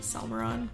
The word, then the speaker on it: Salmeron: Salmeron. Is that how it's supposed to be pronounced, though? Yes. Okay. Every time I talk to someone, it's usually a non-Spanish speaker. – Yeah. – And so I just make it sound Salmeron.